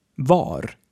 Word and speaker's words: vad: This is also true for var, which is a simple past of vara, to be. So both vad and var are pronounced the same, va.